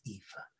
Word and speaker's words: Aoife: My name is Aoife, Mrs. Classy. It's Aoife.